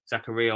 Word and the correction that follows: Zakaria: I don't know how to say it. – It's Zakaria.